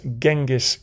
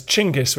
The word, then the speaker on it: Chinggis: This empire was forged by a man we remember as Genghis Khan. We should probably refer to him as Chinggis, which is marginally closer to the original pronunciation.